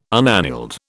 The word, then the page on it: unaneled: British English